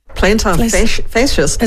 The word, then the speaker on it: plantar fasciitis: Oh dear, it looks like I might have plantar fasciitis. Have I said it right? – Plantar fasciitis.